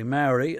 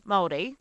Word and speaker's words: Māori: And when you say, and I'm pronouncing it wrongly, Maori, native Maori music, tell me about native Maori music or tell me how to pronounce it properly. – Well, Māori, it's fine if you can't pronounce it.